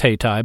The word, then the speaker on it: Hayti: Hayti, pronounced Hayti by its residents, was located south of the cigarette factories along the railroad in Durham.